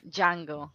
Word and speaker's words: Django: because all I could think of was the Django. Like, that's why. – Yeah. – But this was a good movie. Huh? – The D is silent. – The D. Django. Sorry. Yeah. Django. Django. Django.